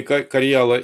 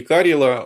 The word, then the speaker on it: Карьяла: Вот тут с ударением, кстати, тоже возможны варианты и Карьяла, и Карела. Оба варианта правильные. Местные жители, конечно, говорят Карела.